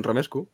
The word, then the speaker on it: romesco: Romescu. Ah, está bien, Romescu.